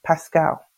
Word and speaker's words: Pascal: So it's Moreen, first name, and then my surname, family name, is Pascal, Pascal, as in the French philosopher.